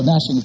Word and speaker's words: gnashing: Or gnashing of teeth is the proper pronunciation. You know, gnashing and gnu.